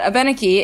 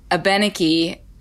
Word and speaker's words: Abenaki: And Abenaki is how the French pronounced that word and how they referred to like the number of different tribes that they were dealing with in colonial times, right?